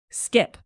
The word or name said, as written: skip